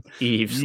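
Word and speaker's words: Yves: Eves, like Christmas Eve.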